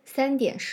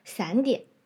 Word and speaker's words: 三点: 比如前面说到的时间三点十五分，三单独发音非常标准，第一声，但是和点连在一起，很多学生会发成散点。或者是三读对了，但是点的读音怪怪的，三电，